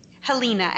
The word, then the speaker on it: Helena: Helena, and thank you for asking. – Helena, I am so sorry. I said it like Helena Montana.